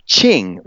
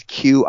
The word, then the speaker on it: Qing: It's a ch sound. So whenever you see a Q, it just means it's like ch. So like you have the word Qing, which is Qing. Mm-hmm. Qing. It's pronounced Qing, but yeah, this is Chufu.